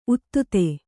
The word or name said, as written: ಉತ್ತುತೆ